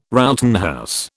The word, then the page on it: Rowton house: British English